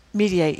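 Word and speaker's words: Mediaite: This guy, Trump, this is Mediate. How do you pronounce it? – Mediate.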